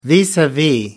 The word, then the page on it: vis-a-vis: American English